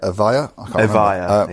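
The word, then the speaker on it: Evija: Not the, how are we pronouncing it? Avaya? – Avaya, yeah.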